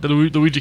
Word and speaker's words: Luigi: They've actually called him Luigi now. – Yeah, it's his official name, Luigi. that's how they say it. You gotta say it that way.